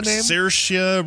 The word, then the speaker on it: Saoirse: How did you pronounce Saoirse Ronan's name? – Saoirse Ronan. – Saoirse. Rhymes with inertia.